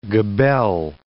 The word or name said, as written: gabelle